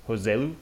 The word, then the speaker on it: Joselu: Um, you know, got a couple Spaniards coming to the game, Jesé and um, Joselu, is that how you pronounce it, on Newcastle? – Uh, yeah.